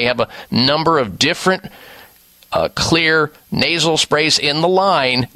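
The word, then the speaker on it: Xlear: And clear is spelled with an X. X-L-E-A-R.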